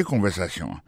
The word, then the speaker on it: conversation: Écoutez conversation. mon conversation, accent tombé sur avant-dernier syllabe là, you know.